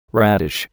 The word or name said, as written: radish